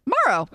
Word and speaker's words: Morrow: Morrow. – Okay. I was overthinking it. – The sun will come out tomorrow.